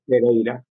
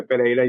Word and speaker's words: Pereira: My name is Jose Pereira. Jose Pereira is my second name. Jose. So I'm Jose Pereira.